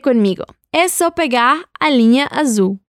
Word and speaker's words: azul: Y sí, lo sé, la palabra azul suena diferente. La L al final suena como una U y la Z suena como una abeja.